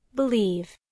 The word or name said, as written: believe